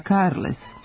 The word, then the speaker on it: Carles: Izvorno piše se Carles i izgovara Carles.